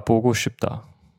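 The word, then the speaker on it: Bogo Schipta (보고 싶다): Habe ich es richtig ausgesprochen? Bogo Schipta? – Ja, Bogo Schipta.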